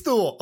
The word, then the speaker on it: Thule: Dude, that's like worse than the time that someone was like, it's called Thule. I'm like, it's spelled like Thule. So I think it's Thule. – Yeah. Like don't, the Swedish, they got a different word for everything. I tell you, they should don't spell it like it rhymes with mule. If you're going to pronounce it Thule.